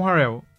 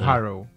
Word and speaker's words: Harrell: É ele bota, ele faz uma com o Harkless, com Beverly, com Kawhi, com Paul George e com Harrell. – O é. Harrell.